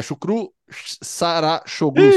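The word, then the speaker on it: Şükrü Saracoğlu: Chucru Sarachoglu, se eu não me engano, a pronúncia correta.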